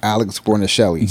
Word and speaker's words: Guarnaschelli: And Chef Alex Guarnaschelli. – Yes. She, listen, I butchered her last name. – Oh, yeah. Guarnaschelli. I got you.